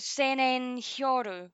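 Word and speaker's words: Sennen Hyōrō: Which was Senen Hyoru. – Yes. – Is that right? Did I say that right? Yeah.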